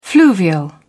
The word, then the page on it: fluvial: British English